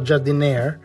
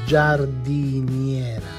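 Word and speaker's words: giardiniera: Well, that for sure is not jardinière, but it's jardiniera.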